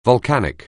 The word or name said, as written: volcanic